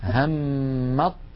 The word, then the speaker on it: همت: والمثال الرابع والأخير همت طائفتان، همت، لاحظوا لا أنطق التاء أبدا، همت طائفتان، ننطق طاء مشددة تحولت التاء إلى طاء ثم أضغمت الطاء في الطاء فصار النطق بطاء مشددة.